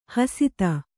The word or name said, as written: ಹಸಿತ